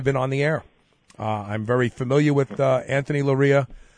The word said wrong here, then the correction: Loera: Did I pronounce his name right? I've never spoken to him. I've only emailed him. – Loera, yeah. – Loera, okay. Yeah.